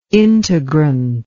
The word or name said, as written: integrant